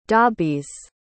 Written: dobbies

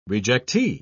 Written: rejectee

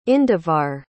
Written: indivar

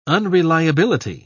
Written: unreliability

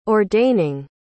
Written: ordaining

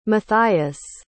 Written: mathias

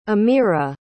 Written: Aamira

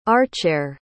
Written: archere

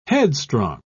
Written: headstrong

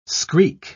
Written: screak

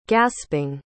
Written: gasping

